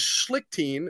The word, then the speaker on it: Schlichting: Also, my last name is Schlichting, which is brutal.